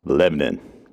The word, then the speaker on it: Lebanon: And in both places, there is a town which is spelled like Lebanon, but pronounced Lebanon.